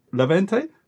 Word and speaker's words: Levante: And they lost this weekend to Levante.